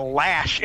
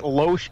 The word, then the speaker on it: Loesch: You know, and another thing, today I listened to the Dana Lash, and it is Lash. We pronounced it Loesch. It's Lash. – It's apparently. – Lash. It's Lash. L-A-S-H.